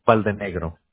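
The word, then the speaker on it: Baldenegro: Valdenegro. – Could you spell that? – Sure. It's B as in boy, A-L-D-E-N-E-G-R-O.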